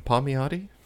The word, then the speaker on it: Palmiotti: Palmiotti? How do you... Anyway. – As Italian as you can get it, I think, is how you say it.